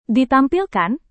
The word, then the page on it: ditampilkan: Indonesian